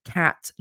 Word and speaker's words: Kat: Another book that I am currently arc reading is called The Reaper's Voice by Kate Bethel. I hope I'm pronouncing her surname right. It's B-E-T-H-A-L. Cat, not Kate. Sorry, I even said that wrong.